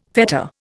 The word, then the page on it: fetter: Indian